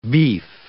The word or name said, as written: beef